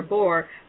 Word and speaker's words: Bohr: So based on Bohr, I don't know if you know who he is, B-O-H-R, if I say his name right. – Sure, Niels Bohr.